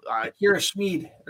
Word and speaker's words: Akira Schmid: Akira Schmid.